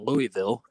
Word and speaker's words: Louisville: But the way it works out is really weird because in Louisville, or in Louisville, as some people pronounce it, in Louisville, on Tuesday nights, they were actually a week behind.